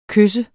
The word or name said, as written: kys